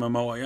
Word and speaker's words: Momoa: Jason Momoa? – Jason Momoa, yeah.